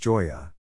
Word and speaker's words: Gioia: Also, Ted Gioia writes on many other topics as well, and is one of my favorites on Substack, and BTW, it's pronounced Gioia.